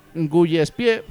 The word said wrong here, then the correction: Gillespie: No, joder, me pareció que era Gillespie, pero yo qué sé. – Ah, Gillespie, oh. – Un poco raro tú pronunciando. – Gillespie, perdona, ¿eh? – A tu rollo.